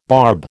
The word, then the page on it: barb: American English